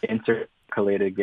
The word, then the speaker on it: intercalated: Is that how you pronounce that? The intercalated games, yeah.